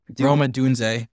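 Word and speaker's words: Rome Odunze: And if you look at receivers, you have the Washington receiver, Rome Diaz, that's how you pronounce it. – Roma Dunze. – Thank you.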